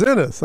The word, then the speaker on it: Enos: Oh, it's Enos. I mispronounced it.